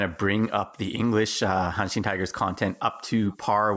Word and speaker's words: Hanshin: And it's pronounced Hanshin Tigers. I want to make sure I'm saying it correctly. – Yeah, that's right. Hanshin.